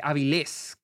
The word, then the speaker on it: Avilés: Pero bueno, amigos, hablemos de Totem, la cual es dirigida por eh, Lila Avil, eh, Avilés, con acento en la Avilés, Lila Avilés, y